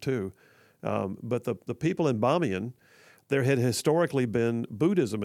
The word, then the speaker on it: Bamiyan: The Bamiyan Buddha, Bamiyan most often is spelled B-A-M-I-Y-A-N, Bamiyan.